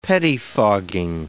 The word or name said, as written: pettifogging